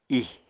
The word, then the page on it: ih: apatani